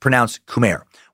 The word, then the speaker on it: Khmer: And by the way, it is pronounced Khmer. Watched a lot of docs for this one. Looked at several pronunciation guides. It seems to be said that way every time.